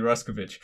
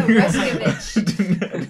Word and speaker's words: Ruskovich: And it was given to an American named Emily, I'm going to butcher her last name. I think it's Rushkowitz or something like that. – Oh. – She wrote a novel called Idaho. – That's pretty awesome. How expensive is the award? – 100,000 euro. – That's pretty. – Oh my god, her last name is Emily Ruskovich. – There you go, Ruskovich. – didn't butcher it at all.